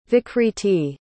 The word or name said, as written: vikriti